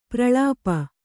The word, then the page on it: ಪ್ರಲಾಪ: pralāpa